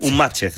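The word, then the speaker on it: Unmatched: Un matchet. Sí, esto escrito literalmente, que es como lo pronuncia todo el mundo, es un matchet, ¿vale? – Vale.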